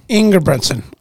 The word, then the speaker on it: Engebretsen: How do you pronounce that last name, Eric? – Engebretsen. – Okay. – There's no L.